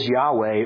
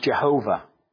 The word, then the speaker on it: Yahweh: And the name there is Yahweh, or sometimes pronounced Jehovah. YHWH in Hebrew.